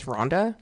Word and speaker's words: Rhonda: my first name is Rhonda. And every person, you know, they hear my name. So first of all, I have a silent H.